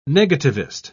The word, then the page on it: negativist: British English